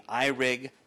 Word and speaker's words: iRig: Today I want to talk to you about a unique microphone called the iRig Mic. It's exactly how it's spelled, I-R-I-G. M-I-C from IK Multimedia.